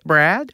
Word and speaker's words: Brad: Brad. I wanted to put some emphasis on the rad part of the Brad.